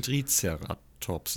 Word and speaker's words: Triceratops: seinem kleinen Triceratops. Ist das richtig ausgesprochen? – Ja.